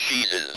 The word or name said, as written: cheeses